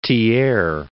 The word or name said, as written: Thiers